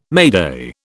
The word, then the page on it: mayday: British English